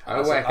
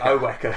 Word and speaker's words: Owaka: So you pronounce it Owaka, okay? Because my mum pronounced it like a Southlander. – Owaka. – Owaka.